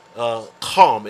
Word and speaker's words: Ham: And it says, the sons of Ham, Cam, it's pronounced Cam in the Hebrew. The sons of Cam, we pronounce it Ham in English, is Cush.